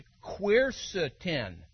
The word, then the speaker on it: quercetin: The key ingredient is quercetin, pronounced quercetin.